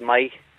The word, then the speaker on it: Meigh: Well, it's just outside Fork Hill, a wee place called Mai. Outside Fork Hill. – How do you spell it? – M-E-I-G-H. – Oh, Mike Nye. – And my. – Right. – As and my. – N or M? – M. M for mother. – All right. My. Right. My. – My. – I've never heard of my. Uh-huh. Yeah. M-E-I-G-H.